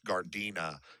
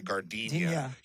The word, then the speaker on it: Gardena: He couldn't tell you, you know, how to pronounce Gardena. He'd say Gardenia.